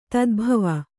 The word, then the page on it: ತದ್ಭವ: tadbhava